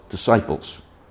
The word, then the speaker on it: disciples: A church is a body of disciples. D-I-S-C-I-P-L-E-S. You don't pronounce the S, but it's still there. Disciples.